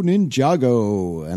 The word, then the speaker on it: Ninjago: And they say it right at the beginning, it's Lego Ninjago.